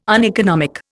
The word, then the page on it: uneconomic: Indian